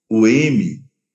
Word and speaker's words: Om: Lembre-se, sempre que você pronuncia a letra M, a boca fecha no final. Om, Ram, Lam, Sham.